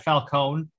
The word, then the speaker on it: Falcone: And that it had multiple, like, I guess minor antagonists besides the Riddler, because it was like they had Falcone. That's actually the name you pronounce, the way you pronounce it.